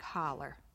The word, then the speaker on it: Hollow: Now, you know that's spelled H-O-L-L-O-W. It's known now as Highway 96, but the old-timers call it Walnut Creek Holler.